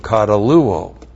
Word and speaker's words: kataluo: Now, we have aeon plus the subjunctive of kataluo. And now you know it does not mean to dissolve. K-A-T-A-L-U long O. Kataluo.